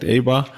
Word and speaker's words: Eibar: Eibar, ich weiß nicht, ich hätte jetzt Eibar gesagt. Ihr sagt Eibar. – Ja, ist die deutsche Aussprache, ist okay.